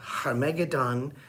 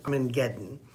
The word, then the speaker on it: Armageddon: or Harmageddon, or as most people pronounce it, Armageddon.